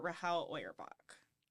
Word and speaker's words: Rahel Auerbach: so there are a couple different pronunciations mm-hmm. of Rachel's name. Rahel Auerbach, Rahel Auerbach, Rachel Auerbach, However, um, I am going to stick with a pretty much standard for Rahel Auerbach. Rahel? For the podcast.